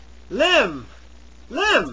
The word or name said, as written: limb